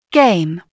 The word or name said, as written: game